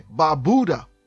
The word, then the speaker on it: Barbuda: Okay, the other island is the one Barbuda, B-A-R-B-U-D-A. You hear some say Barbuda. That is not the pronunciation of the name. It is Barbuda. We've got the island of Anguilla, A-N-G-U-I-L-L-A, and you hear Anguilla.